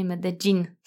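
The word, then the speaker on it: Medellín: Antioki, czyli regionu, w którym, którego stolicą jest Medellin, powiedzą właśnie Medellin. tak jakby dżin wyskakiwał z lampy.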